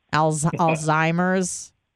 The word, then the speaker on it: Alzheimer's: Alzheimer's? – Excellent.